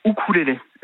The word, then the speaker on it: ukulélé: Alors, normalement, on dit ukulélé. Le U en océanien se prononce ou.